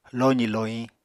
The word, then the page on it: lozì-lohiñ: Apatani